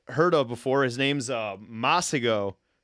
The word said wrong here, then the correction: Masego: or yeah, Masego.